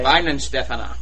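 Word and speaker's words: Weihenstephaner: Weinhenstephan. – If it's got a W, it's a V. – Yeah. – Oh, okay. Weinen Stefana.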